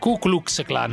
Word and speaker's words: Ku Klux Klan: È Radio Cucus Clan. – Ku Klux Klan, non Ku Klux.